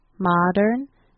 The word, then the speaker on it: modern: And for modern, the M-O is not mo, it's pronounced ma, M-A, ma, modern. And last word, stomachs. Sometimes the A-C-H-S is a little bit hard to pronounce, but you want to pronounce it as ix, stum-ix.